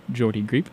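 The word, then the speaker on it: Geordie Greep: But the first song we listened to was Walk Up by Jordy Greep. I think it's a soft G, Jordy Greep.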